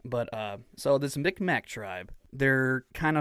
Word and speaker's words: Mi'kmaq: This is from the Mi'kmaq tribe. I hope I'm saying that right. M-I-C-M-A-C. Mi'kmaq. – Okay. – Is that correct? – That sounds about right.